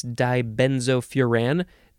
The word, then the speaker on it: dibenzofuran: Dibenzofuran. Dibenzofuran. Dibenzofuran. You can also say dibenzofuran. You can emphasize the last syllable.